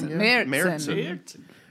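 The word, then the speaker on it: Märzen: Marzen? Did I say it wrong? – Marzen.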